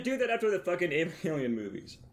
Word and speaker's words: Evangelion: How do you pronounce Evangelion? – Evangelion.